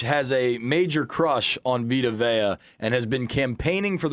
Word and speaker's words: Vita Vea: Oh, Vita Vea.